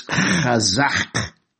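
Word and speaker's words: chazak (חזק): It's chazak. You have to pronounce it from deep into your chest there.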